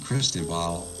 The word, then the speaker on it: Cristobal: When that occurs, it will be named Cristobal, pronounced K-R-E-S-T-O-H-Bal.